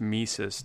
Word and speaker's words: Mises: So then the fact that it's pronounced Mises doesn't inspire you to say that you love Mises to pieces? – No, it doesn't.